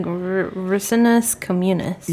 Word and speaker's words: Ricinus communis: Its Latin name is like Rycnus communis or something like that. How do you say it? Because you're better at shit. – I think it's like Ricinus communis.